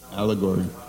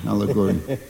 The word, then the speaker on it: allegory: Allegory, that's how you say it? – Allegory. – Allegory. – Not alligator, allegory.